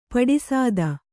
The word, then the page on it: ಪಡಿಸಾದ: paḍi sāda